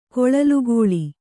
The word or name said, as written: ಕೊಳಲುಗೋಳಿ